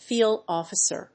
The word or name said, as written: field officer